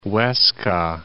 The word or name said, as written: Huesca